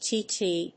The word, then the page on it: CTT: 英